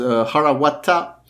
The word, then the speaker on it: Harawata: And additionally, there's a bit of a pun to it because Harawata, pronounced closely but separately, it's Wata.